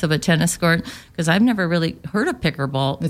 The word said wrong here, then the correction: pickleball: It's pickleball.